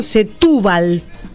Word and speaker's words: Setúbal: En una laguna que se llama, espero pronunciarlo bien, Setúbal. Con acento en la U, ¿sí? – Mostraba especies de patos.